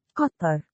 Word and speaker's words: Qatar: What pronunciation is that? – That's the Arabic. – Give it to me again. – Qatar.